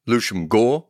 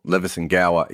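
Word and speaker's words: Leveson Gower: The MCC is represented by Lord Hawke, Australia by Les Poitevin and Peter McAllister, South Africa by Shrimp Lushem Gore, or Levison Gower if you're reading it phonetically, and a gold tycoon called Abe Bailey.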